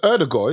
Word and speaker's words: Odegaard: It's Odegaard, isn't it? I think people are going to probably, in England, they're going to say Odegaard because like we're so lazy in the pronunciation, but it's Odegaard.